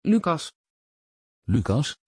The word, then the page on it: lucas: Dutch